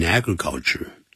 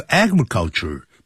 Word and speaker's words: agriculture: A-C-H-I-E-V-E 把 E 去掉加 I-N-G Achieving success 要这么说还有 agriculture 不要念成 agriculture、啊、 agriculture 重音在第一音节